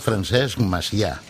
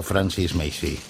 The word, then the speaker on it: Francesc Macià: Es pronuncia Francesc Macià, no Francis Macy.